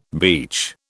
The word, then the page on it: beach: British English